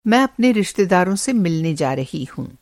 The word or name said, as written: रिश्तेदार